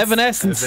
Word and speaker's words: Evanescence: Evanescence.